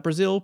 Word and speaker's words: Brazel: It's Brazil, pronounced like frazzle with a B, he said.